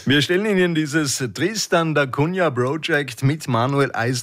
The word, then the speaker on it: Tristan da Cunha: The Tristan da Cunha Project? – Ja, ist perfekt ausgesprochen.